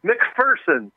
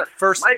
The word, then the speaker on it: Macpherson: Macpherson. – It is, yes. Macpherson.